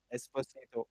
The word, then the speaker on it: Esposito: I don't know how to pronounce it, but yeah. – Esposito. Yes. – Esposito? – Yes, okay, yes.